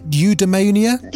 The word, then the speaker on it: eudaimonia: Am I pronouncing it right? Eudaimonia? – Yeah, that's right.